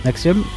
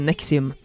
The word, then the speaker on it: NXIVM: Nexium, som heter, altså N-X-I-V-M. – Ja, så det litt sånn, jeg synes det var vanskelig riktig hvordan jeg skulle uttale det, ja. Men I dokumentaren sier de Nexium.